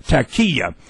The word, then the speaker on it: taqiyya: So I wrote T-A-C-K, tack, and the emphasis is on the K, tack, and the E is long, and ya is a silent A, taqiyya. And it gives them the permission to lie.